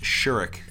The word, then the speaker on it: Shorrock: But the main song is American Flyers by Glenn Shorrock. And it's pronounced Shurik. – Sorry. – Because he's Australian and that's how they pronounce it there.